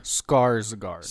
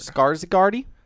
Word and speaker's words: Skarsgård: Skarsgård. – Skarsgårdy? – Like a scar, like a, I'm cut, now it healed, I have a scar.